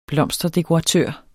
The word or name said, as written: blomsterdekoratør